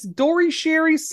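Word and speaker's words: Dore Schary: And then they get a call, and it's Dori Sherry's secretary, who Dori Sherry is spelled Dori Schary, and it's a man.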